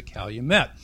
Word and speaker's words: calumet: It's the English pronunciation, like valet is valet in English, and fillet of fish is a fillet of fish. So the calumet is a calumet.